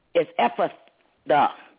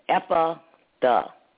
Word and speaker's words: Ephrathah: I looked it up and I try to get the right pronunciation, but it's Ephrathah. That's the way you pronounce it, Ephrathah.